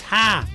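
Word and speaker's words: ha (ห้า): Because in Thai, the number 5 is pronounced ha.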